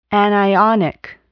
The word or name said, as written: anionic